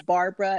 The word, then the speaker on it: Barbara: Yeah, my station name is Why Struggle, but the name is Barbara, and that's B-A-R-B-A-R-A. The last name is spelled Faison, F-A-I-S-O-N.